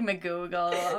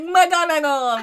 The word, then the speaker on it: McGonagle: People are like, McGoogle. I'm like, no. – I was like, McGonagle.